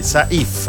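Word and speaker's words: Sayf: L'accento cade sulla I, quindi Saif, praticamente.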